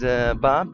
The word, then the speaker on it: Bob: My name is Bob, B-O-B.